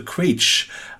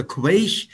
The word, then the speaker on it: Quaich: Da steht Keeper of the Quage. Ähm, Habe ich es richtig ausgesprochen und was bedeutet das? – Ja, ähm, du hast es richtig ausgesprochen. Ähm, Keeper of the Quake ähm, sagen viele.